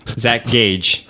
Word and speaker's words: Zach Gage: It is a game by Zach Cage. One of those names. – Yeah. – Zach Gage. – Yeah, it's hard. There's got a hard K and a hard G.